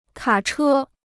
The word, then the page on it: 卡车: kǎ chē